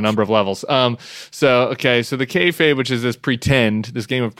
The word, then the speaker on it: kayfabe: kayfabe, which I've mispronounced for years. I appreciated your etymology of it rhymes with hey babe in the book.